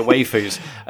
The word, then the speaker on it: waifus: I pronounced it waifus. I've realised I've never actually heard the word said. I've seen it. And, like, it's W-A-I-F-U-S? – Yeah, so it's waifus. – Like, waif? – As in...